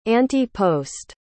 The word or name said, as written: ante-post